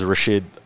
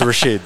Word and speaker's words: Rachid: Because it's French spelling, it's spelled R-A-C-H-I-D. So it's a bit hard for a lot of people to pronounce, or they'll just call me Richard, which I was like, bro, my name isn't Richard. It's Rashid.